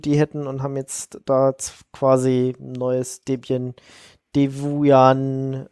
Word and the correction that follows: Devuan: Es wird Devuan ausgesprochen, habe ich vorher gerade gelesen.